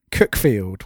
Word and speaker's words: Cuckfield: Interestingly enough, I realised the other day, I saw on Twitter people in the US had found Cookfield, which is a town, a village very close to where I live. But obviously, it's spelt Cuckfield. – It's spelt Cuck. And all of these people on Twitter from the US were losing their minds over the fact that there's a place called Cuckfield in the UK. I didn't have the heart to butt in and say, I'm afraid it's not pronounced that way.